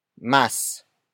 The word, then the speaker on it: mas: Higit na is spelled as H-I-G-I-T space N-A. It also means more, but mas is much preferred by Tagalog speakers because of the convenience of saying one syllable instead of three. The next word is masipag. Masipag. Masipag. Masipag is spelled as M-A-S-I-P-A-G.